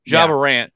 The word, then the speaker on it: Ja Morant: Jamon Moore? Ja Morant.